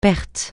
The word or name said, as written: perte